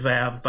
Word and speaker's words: waw: Which I'll do in just a second, but it is Vav by, or Vav is the, I believe, the correct pronunciation of it, even when it's spelled W-A-W.